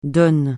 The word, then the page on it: DON: francais